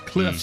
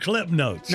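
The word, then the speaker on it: CliffsNotes: Cliff's notes, and you said it correctly, because it is Cliff's notes. Okay, see, well, I thought it was a misprint, because I always heard Cliff notes. – No, it's Cliff's possessive.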